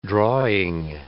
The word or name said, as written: drawing